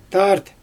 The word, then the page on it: tart: Xyzyl